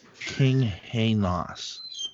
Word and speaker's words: Kinghanus: His first name was L-E-E. His last name was K-I-N-G-H-A-N-U-S. It's pronounced Lee Klinghanos. Am I missing something here? Lee Klinghanos. – King Hainos. Lee King Hainos. Oh, Lee King Hainos.